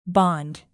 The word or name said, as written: bond